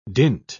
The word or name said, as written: dint